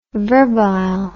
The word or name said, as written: verbile